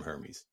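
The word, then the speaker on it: Hermès: Yeah, $349 just for the leather piece to go around the AirTag from Hermes. – It ain't that serious, man. – That's highfalutin, brother. – It's not that serious. Outside of and apparently the H is silent. Hermes.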